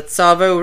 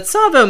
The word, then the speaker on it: Tsavo: Tsavo River. It's spelled T-S-A-V-O, so it's sort of a ts sound.